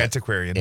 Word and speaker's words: antiquarian: Yeah, antiquarian. – Antiquarian. – Yep. Okay. – Close. You accent on the wrong syllable.